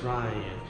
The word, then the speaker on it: Ryan: Yo, my name is Ryan, It rhymes with Brian Fierce like a lion.